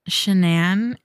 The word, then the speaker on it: Shanann: And the night that it happened, Shanann is the, I'm pretty sure that's how you say her name.